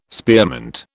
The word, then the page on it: spearmint: British English